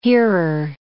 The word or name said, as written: hearer